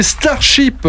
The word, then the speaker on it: Starship: On vient de s'écouter Starship, comme Starship Trooper. Hein, voilà, ça va peut-être vous aider à prononcer le nom.